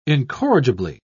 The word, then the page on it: incorrigibly: British English